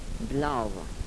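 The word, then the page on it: blavo: dialetto croato di Lukezi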